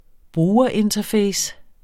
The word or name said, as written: brugerinterface